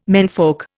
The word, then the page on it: menfolk: Indian